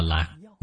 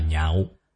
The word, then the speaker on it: nhạo: Chữ lạc này đọc trại âm, không đọc là lạc, mà đọc là nhạo.